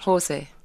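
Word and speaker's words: José: Now, I'm told that you're a good friend of, is it Jose or Jose Carreras? – Jose. – How does he pronounce it? – Jose. – Where's he from? – Spain. – So it's Jose. It's Jose when they're from Portugal, isn't that correct? – He would say he's Catalan and not Spanish.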